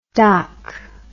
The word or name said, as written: dhak